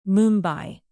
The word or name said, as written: mumbai